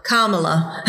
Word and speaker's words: Kamala: I think like Yamaha, Kamala.